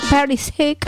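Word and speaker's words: Perišić: A ver si lo dije bien, ¿no? Perisic. Sí, adelantó a Croacia, sí, pero Cristiano Ronaldo empató de penal.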